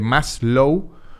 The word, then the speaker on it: Maslow: Esto se llama la pirámide de Maslow, así se escribe, la pirámide de Maslow, Low como debajo y más como de más sería como más abajo, como si estuviese uh, bailando reggaetón, sería un reggaetón más slow.